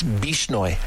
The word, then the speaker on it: Bishnoi: I was calling it the Bolshnoi gang, but I think I was mixing up the old Russian term with the new one. It's Bishnoi, if I've got it correctly, but some wild, wild things going on with that group.